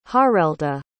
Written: harelda

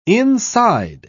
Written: inside

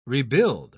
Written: rebuild

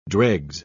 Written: dregs

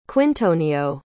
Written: quintonio

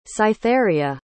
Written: cytheria

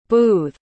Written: boothe

